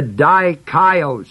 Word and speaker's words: dikaios: The word just is the Greek word spelled D-I-K-A-I-O-S. Dikaios, it's an adjective form. Its basic root form is the word dike, spelled D-I-K-E, long E.